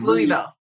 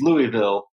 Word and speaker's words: Louisville: So I know in Colorado there is a Louisville. And so lots of people from Denver call it Louisville. – It's Louisville. – It's Louisville. So we get that from Denver people a lot.